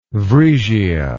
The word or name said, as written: vriesia